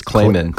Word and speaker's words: Kleiman: Am I mispronouncing his name? – That's how it was said to me by someone involved in the case. Kleiman.